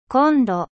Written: cuisiniere

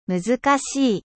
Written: difficile

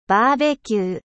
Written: barbecue

